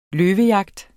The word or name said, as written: løvejagt